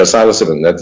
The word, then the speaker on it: psilocybin: psilocybin, or however you pronounce it. – Yeah, psilocybin.